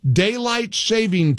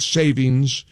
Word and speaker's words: daylight saving: President Trump wants daylight saving time. And I want to make sure I say it right. Daylight saving, not savings. You always want to say savings, but it's saving apparently.